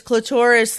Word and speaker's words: clitoris: Well, because clitoris likes Dolores. – Right. – Because it exactly. rhymes with Dolores.